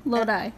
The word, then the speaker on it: Lodi: Lodi. – Lodi? L-O-D-I?